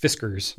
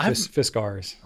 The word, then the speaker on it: Fiskars: It's not pronounced Fiskars. – Really? – No, it's Fiskars.